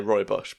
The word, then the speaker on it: rooibos: I had Damo Clark on it, and he kept pronouncing it rooibos, and I now confidently say rooibos because of him.